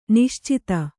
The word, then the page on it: ನಿಶ್ಚಿತ: Kannada